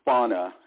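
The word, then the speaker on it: Fani: If any one of Fannie Willis's employees, I think it's pronounced Fauna, it's different from the way it's actually spelled,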